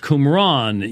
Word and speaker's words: Qumran: We've looked at portions from the Dead Sea Scrolls from Qumran, which is how it's pronounced, Qumran, yes.